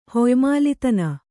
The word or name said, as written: ಹೊಯ್ಮಾಲಿತನ